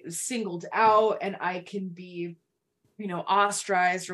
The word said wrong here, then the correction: ostracized: Ostracized. You were close. You were missing one syllable.